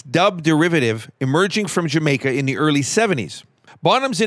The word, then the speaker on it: D'yer Mak'er: Now, the title of this next song is actually pronounced Jamaica, as spoken by the locals in that country. It's a play on the phrase, Did You Make Her?